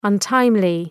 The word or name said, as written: untimely